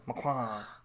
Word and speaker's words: Macron: You gotta say like a French person. Macron.